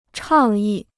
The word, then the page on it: 倡议: chàng yì